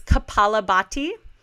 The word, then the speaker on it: Kapalabhati: The fancy Sanskrit word for it, or Sanskrit if you want to pronounce that way, is Kapalabhati.